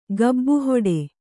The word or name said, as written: ಗಬ್ಬುಹೊಡೆ